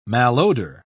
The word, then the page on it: malodor: British English